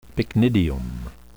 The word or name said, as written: pycnidium